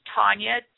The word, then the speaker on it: Tonya: If you're wanting to reach me or like to book me to speak, you can go to my personal website, which is Tonya, T-O-N-Y-A, Hoffman, H-O-F, like Frank, M-A-N-N, two N's, .com.